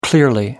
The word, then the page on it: clearly: American English